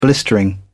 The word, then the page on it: blistering: British English